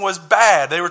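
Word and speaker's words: bad (בד): The Hebrew word for alone is bad. That is how it's pronounced. That is how it is written, is the word bad.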